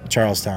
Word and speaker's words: Charlestown: same thing with Charlestown.